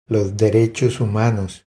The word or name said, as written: Los derechos humanos